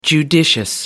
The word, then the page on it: judicious: American English